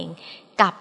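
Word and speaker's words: กัป: กับนี้ก็สะกดกอไก่ไม่หันอากาศปอปานะคะหรือจะสะกดว่ากอไก่ไม่หันอากาศลอลิงปอปากะลันก็ได้ค่ะเขียนได้สองอย่างแต่ก็อ่านออกเสียงเหมือนกันคืออ่านว่ากับนั่นเองคำว่ากับมีอยู่ได้กัน 4อย่างค่ะคือ 1.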